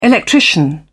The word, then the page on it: electrician: British English